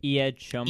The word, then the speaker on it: Eyad Shouman: Iyad Shoman.